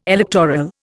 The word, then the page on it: electoral: Indian